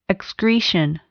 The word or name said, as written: excretion